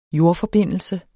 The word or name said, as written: jordforbindelse